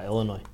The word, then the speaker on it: Illinois: Illinois, how do you say it? – Illinois.